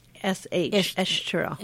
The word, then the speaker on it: Estoril: So that's one of the things that you Well, I'm corrected already because I called it Estoril. The S again pronounced Estoril. Estoril. – You know, Portuguese have that sound in their language.